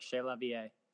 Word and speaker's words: Chez La Vie: Is it Chez La Vie? Am I butchering that? – Yeah. No, it's perfect. Chez La Vie.